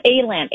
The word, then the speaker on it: Aland: Is it A-Land or all? How do you pronounce it? – I think it's A-Land. A-L-A-N-D.